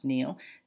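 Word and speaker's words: Neale: So a quote from Neil, and he spells his name N-E-A-L-E, I think it's pronounced Neil, Donald Walsh.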